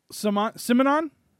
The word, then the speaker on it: Simonon: Oh, I can't say his name. What is it? Simone? Was that his name? – Simonon? Yeah.